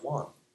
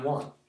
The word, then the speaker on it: Juan: My name in Spanish is pronounced Juan. I'm Juan.